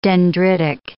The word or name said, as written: dendritic